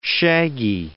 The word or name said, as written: shaggy